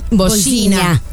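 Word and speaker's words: Vozinha: ¿Cómo se pronuncia? – Bojina. La NIH es ña.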